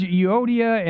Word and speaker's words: Euodia: I urge Euodia and I urge Syntyche, is how you say that.